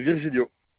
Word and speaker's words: Virgilio: Mon nom, c'est Virgilio. – Ça s'écrit V-I-R-G-I-L-I-O. – Et le prénom? – Johan, Y-O-A-N.